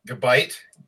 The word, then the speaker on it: Gabite: Gabite? – Yes.